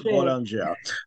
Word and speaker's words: Boulanger: Is that how you pronounce it? – George Boulanger.